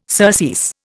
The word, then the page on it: surcease: Indian